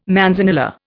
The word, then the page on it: manzanilla: Indian